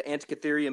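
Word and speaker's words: Antikythera: And I guess the three main ones we want to kind of zero in on today are the Antikytheria mechanism. – Wow, good job on that pronunciation.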